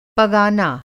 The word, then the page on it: पगाना: Hindi